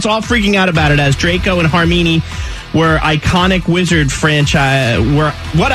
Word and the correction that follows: Hermione: Is it Harmony? Because I don't... – Hermione. Yeah.